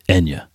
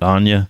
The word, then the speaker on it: Enya: The correct pronunciation for the Celtic singer Enya is Enya, not Anya.